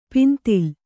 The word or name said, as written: ಪಿಂತಿಲ್